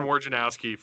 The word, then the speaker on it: Wojnarowski: So going and skipping over here to the Adrian, how do you say it? Wojnarowski, is that how you say his name from ESPN? – Yeah, Adrian Wojnarowski he's the ESPN insider for NBA.